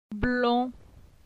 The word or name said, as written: Blon